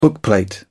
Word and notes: bookplate: British English

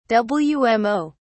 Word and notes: wmo: British English

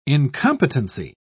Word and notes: incompetency: British English

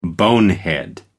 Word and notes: bonehead: American English

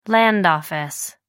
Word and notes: land office: American English

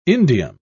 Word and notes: indium: British English

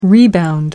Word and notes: rebound: American English

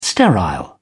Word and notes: sterile: British English

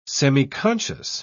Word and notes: semiconscious: British English

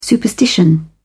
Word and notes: superstition: British English

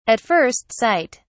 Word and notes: at first sight: British English

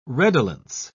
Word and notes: redolence: British English

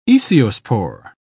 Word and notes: aeciospore: British English

